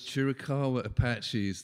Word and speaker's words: Chiricahua: Help me with the pronunciation of this Indian tribe we talked about earlier, the Chiricahuas? – Chiricahua Apaches. This was Geronimo and Cochise, their people.